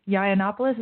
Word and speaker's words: Yiannopoulos: Rob says it made him spend too much time reading Milo's Yiannopoulos, is how you pronounce his name.